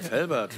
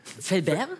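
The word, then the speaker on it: Fellbach: Es gibt aber so viele Leute sprechen das falsch aus, weil wir auf der anderen Rheinseite sind, die denken, das ist schon so weit an Frankreich, dass man das... – Felbert? – Ja. Ja. So viele Leute wollen das immer so aussprechen, deswegen, ja, du hast das genau richtig ausgesprochen.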